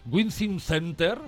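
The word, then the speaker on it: WiZink Center: Bueno, y que se celebra aquí en el Palacio de los Deportes de Madrid, en el Wizzing Center. – Lo has pronunciado muy bien.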